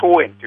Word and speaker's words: 桑園: 桑に園と書いて桑園という駅がありますけれども、その近くです。